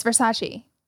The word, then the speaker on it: Versace: Addison Rae under heat for pronouncing Versace wrong. – Oh yeah, because it was Versace, Versace. – What did she say? – Oh God, Versace. – And it's Versace.